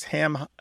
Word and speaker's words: hammock: Hammock tends to have a stress on the first syllable, where hammock tends to have a stress on the second syllable.